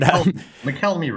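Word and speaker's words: Mokelumne: I mean, the main supply for EB Mud comes right from the Party Dam on the McElumna River, right? I'm not sure I said that right, but McElmy River.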